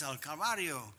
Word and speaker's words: al Calvario: and the pastor's wife stood up and she corrected me and she said, no, it's al Calvario, and I said, gracias Calvario, and everybody started laughing.